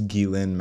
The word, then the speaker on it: Ghislaine: Which is a little ironic that she has jizz in her name and she's a pedophile, but it's Ghislaine Maxwell.